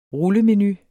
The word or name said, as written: rullemenu